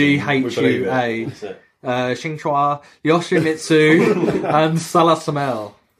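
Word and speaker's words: Xianghua: Shanghua.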